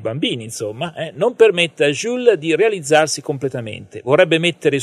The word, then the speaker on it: Jules: La lunga relazione tra Nick e Jules, non so se è corretta la pronuncia, come al solito, ha permesso, giusto. – È giusto, grazie,